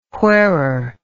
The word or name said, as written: wherere